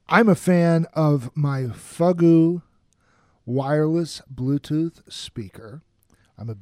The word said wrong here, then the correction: Fugoo: Are you sure you're pronouncing that right? – It's Fugoo.